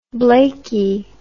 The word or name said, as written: Blakey